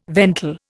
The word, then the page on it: ventil: Indian